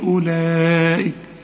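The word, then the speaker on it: أولئك: وارجع برضو واسمع الشريط، ورسله اولئك. الواو بقى اللي مرسوم عليها الخمسة دي تكتب ولا تنطق، اولئك هم الصديقون.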